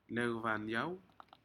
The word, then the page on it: necvamiau: Aneityum